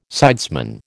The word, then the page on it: sidesman: American English